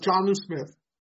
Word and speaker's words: Jonnu: Jonnu, is it Jonnu Smith or Jonnu Smith? – Jonnu Smith.